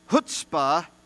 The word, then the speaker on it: chutzpah: chutzpah.